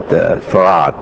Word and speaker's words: Fard: From a man called Farad, spelled F-A-R-D, but we pronounced it Farad, mm-hmm.